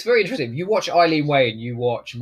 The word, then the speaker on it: Eileen: Eileen Way? – Eileen, yeah.